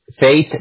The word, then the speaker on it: Faith: Fate Drive es Fate de fe, F-A-I-T-H.